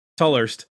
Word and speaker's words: Tolhurst: And then famously, I think Lowell Tolhurst, is that how you say it? – Tolhurst is probably the most famous member besides Robert Smith.